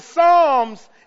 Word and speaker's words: Psalms: Now, the Psalms is in the middle of your Bible. That's where the Psalms is. It's in the middle of your Bible. And it's spelled P-S, not S.